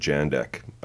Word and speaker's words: Jandek: Do you know if it's Jandek or Yandek? – I've always said Jandek. I'm yeah, me- pretty sure that's how they pronounce it in the documentary as well.